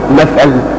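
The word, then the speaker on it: مَثَل: كلمة مثل إذا سمتها الميم والثاء واللام بتنطق كما يأتي، إما أن تنطقها مثل وإما أن تنطقها مثل. مثل بكسر الميم وسكون الساق مش كده؟ ومثل بفتح الميم وفتح الثاء.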